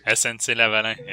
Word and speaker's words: SNC-Lavalin: Yeah, SNC-Lavalin. Yeah, that's how you say it.